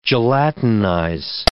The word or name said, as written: gelatinize